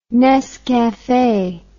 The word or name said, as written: nescafe